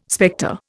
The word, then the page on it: spectre: Indian